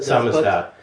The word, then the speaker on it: samizdat: Samizdat, is that how you pronounce it? – Samizdat, yeah.